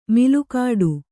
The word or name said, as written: ಮಲಗಟ್ಟು